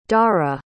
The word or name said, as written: dhara